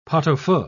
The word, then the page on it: pot-au-feu: British English